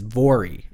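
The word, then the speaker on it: Voirrey: Now, the farm was owned by a Mr. and Mrs. Jim and Margaret Irving and their 12-year-old daughter, whose name, I believe, is Vori. It's V-O-I-R-R-E-Y. So that's how we're going to pronounce that.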